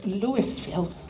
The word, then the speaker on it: Louisville: I have been in Louisville all of my life, and people say it's Louisville, it's Louisville. My mom made us pronounce it that way.